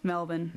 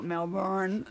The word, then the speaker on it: Melbourne: They're from Melbourne, Australia. Or how would you say that? – Melbourne. – There you go. – Not Melbourne.